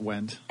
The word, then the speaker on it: Wendt: Yeah, silent D. Just Wendt.